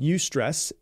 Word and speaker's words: eustress: The first one is called eustress, and it is EU stress. That's how it's spelled. And EU, like EU, basically what it means when you look at it, it's a Greek prefix, which means good. And so eustress basically just means good stress.